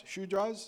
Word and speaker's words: Shudras: Shudras, is that how you say it? – Shudras, I'm speaking some Indian here right now.